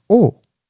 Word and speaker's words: Oh: Like, my last name is Oh.